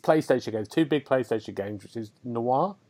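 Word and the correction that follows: Nioh: have I said that right? – Neo, and of course Horizon Zero Dawn, which is one of the best PlayStation games.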